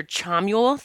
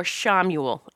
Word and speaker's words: Chamuel: Now, that can be pronounced either Chamuel, the CH sound, or Chamuel, SH. Either way, Chamuel is pronounced either way.